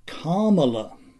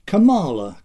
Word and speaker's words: Kamala: To the second issue, the proper pronunciation of her forename, Wikipedia gives Kamala. Eh? Okay. But I think that to the eye of a native English speaker, Kamala comes more naturally.